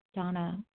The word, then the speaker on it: Dana: This is Donna B., recovered compulsive overeater and bulimic, calling from Denver. I do spell my name D-A-N-A, although it's pronounced Donna.